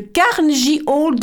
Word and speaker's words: Carnegie Hall: le Carnegie Hall de New York. C'est comme ça qu'on dit Exactement.